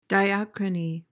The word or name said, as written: diachrony